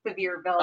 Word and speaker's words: Sevierville: No, it was actually called Sevierville. – Oh, okay. – But we like to call it Sevierville because it sounded Yeah, Sevierville.